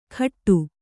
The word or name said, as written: ಖಟ್ಟು